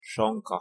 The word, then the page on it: sonka: Hungarian